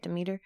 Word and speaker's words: Demeter: Is that how you say it? Demeter? – Yep.